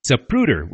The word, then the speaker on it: Zapruder: And by the way, for all of you who've been getting his name wrong for the last 50 years, it's Zapruder with a P, not a B.